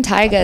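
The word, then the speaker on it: Taiga: The name Tyga is actually from anime as well.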